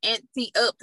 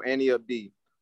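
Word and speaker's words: Ante Up: Yeah, so everybody says that, but it's pronounced like Annie Up, like Annie, and then Up D. So Annie Up D.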